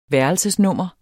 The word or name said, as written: værelsesnummer